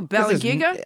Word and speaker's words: Balangiga: Oh, Balangiga? No, that's the right way you say it.